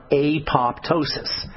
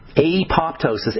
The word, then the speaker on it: apoptosis: And you guys probably heard the term apoptosis. Apoptosis, A-P-O-P-T-O-S-I-S, apoptosis is a very hard to pronounce word, but it just means cell suicide.